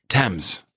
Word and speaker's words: Thames: You know, English is confusing. There are silent letters in it, and you know, yes, Thames. – Thames. – It should almost. How would you spell Thames? – T-E-M-M-S.